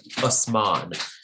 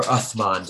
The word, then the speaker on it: Uthman: And at one point, one of the caliphs, a guy named Usman, or Uthman, depending on which pronunciation you use,